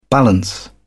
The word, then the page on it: balance: British English